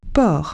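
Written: harbour

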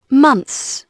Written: months

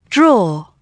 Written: draw

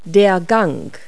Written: corridor

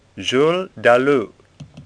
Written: Dalou

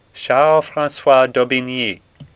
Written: Daubigny

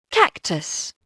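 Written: cactus

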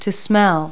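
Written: smell

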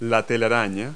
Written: web

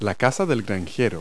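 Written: farmhouse

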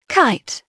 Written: kite

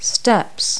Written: steps

